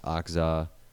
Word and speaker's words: Okja: A viewer and commenter in Korea actually said it's pronounced Okja, but it sounded like Octeo or something, or Okzeo or Okzeo or Okja.